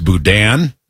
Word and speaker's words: boudin: Apparently, it's pronounced boudin. – Boudin. Got it.